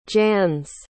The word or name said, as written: jannes